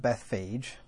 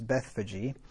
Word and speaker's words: Bethphage: And when they drew nigh unto Jerusalem and were come to Bethphage, and the reason I pronounce it that way is because that's where the diacritical markings in the Cambridge, anyway, say it should be pronounced. It's not Bethphage, according to Cambridge, anyway. It's Bethphage.